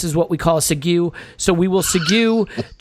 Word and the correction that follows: segue: to segue. – Sorry, my bad. – I've seen it spelt. – I got the pronunciation problem.